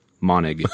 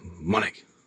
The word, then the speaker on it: Monig: Because I always thought it was Monig. – Monig is the most common mispronunciation. – But you got a nice, firm German last name. – Yes. Monig.